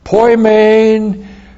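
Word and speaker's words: poimen (ποιμήν): Poimen didaskalos. Two words, but one gift, one person. Poimen, P-O-I-M, long E-N, poimen, and then didaskalos. D-I-D-A-S-K-A-L-O-S, didaskalos.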